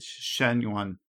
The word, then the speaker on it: Xuan Yuan: I want to say that I think I mispronounced the name. It's Shen Yuan. So Shen for the Xuan Yuan. Shen Yuan Sword 7. Shen, I want to at least I want to make my best attempt to pronounce that correctly.